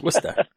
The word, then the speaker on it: Worcester: No, it's Worcester. – It's Worcester. – It's Worcester over here. – It's like Tucson, you know? It's Worcester.